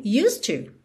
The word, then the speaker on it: used to: used, o E é, não é pronunciado, ok? E o D, que é a consoante oclusiva, eu já ligo no T do to, então você ouve mesmo used to, used to, ok?